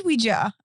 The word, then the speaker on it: Ouija: It is Ouija.